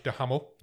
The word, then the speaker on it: Duhamel: This film stars, was written, was directed and produced by Josh Duhamel. – Yeah. – I checked how to pronounce his name and that is how you pronounce it.